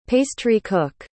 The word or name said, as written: pastry-cook